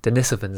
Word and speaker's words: Denisovans: Denisovans.